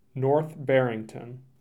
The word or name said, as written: North Barrington